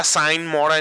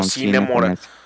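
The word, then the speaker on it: Sine Mora: No, sé si sea Sign Mora en inglés ah, o Cine Mora. – Ajá. Este, he escuchado que le dicen de las dos maneras.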